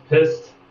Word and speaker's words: PSST: Yeah, it was called PSST, like SST, but with a P before it, pronounced pissed.